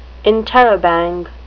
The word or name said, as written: interrobang